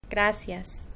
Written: gracias

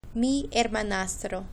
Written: mi hermanastro

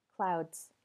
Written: clouds

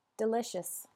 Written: delicious

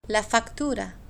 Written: la factura

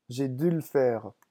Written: j'ai

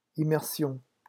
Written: immersion

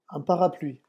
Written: un parapluie

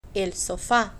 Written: el sofa

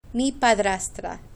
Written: mi padrastra

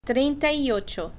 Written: trienta y ocho

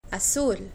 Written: azul

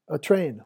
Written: a train

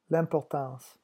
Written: l'importance

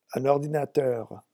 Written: un ordinateur

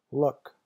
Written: look

